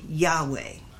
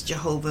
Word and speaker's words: Yahweh: But whenever you see, however, whenever you see capital L, capital O, capital R, capital D, it's normally Yahweh. And I know that we went for at least 100 years thinking that Yahweh was Jehovah and we pronounced it that way. It was an error.